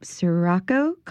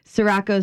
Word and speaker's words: Sirocco: So they are opening up Sirocco coffee. Um, but I know that here in New Orleans, we don't pronounce anything correctly. So Sirocco, Sirocco, you'll answer to either.